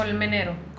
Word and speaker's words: Colmenero: Col- how would how would you pronounce that in English? Oh, Colmenero. – Colmenero. Uh, yeah.